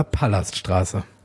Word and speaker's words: Palaststraße: Heißt es eigentlich Palaststraße oder Palaststraße? – Es wird zwar mit einem L geschrieben, aber ausgesprochen wie mit 2 L.